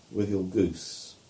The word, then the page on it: Withielgoose: English